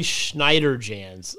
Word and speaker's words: Schneiderjans: Man, for such a guy who prides himself on pronunciation, Ollie Schneiderjans, yeah.